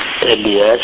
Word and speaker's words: إلياس: النطق صحيح فيه، النطق صحيح فيه إلياس بفتح الهمزة، همزة تواصل